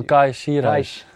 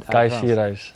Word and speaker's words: Kai Sierhuis: It's Mitchell Bakker at Paris Saint-Germain, and I'm going to pronounce his name badly, but Cage Sierus at Reims. Cage Oh, Kai Sierhuis. Rijms, yeah. Kai Sierhuis.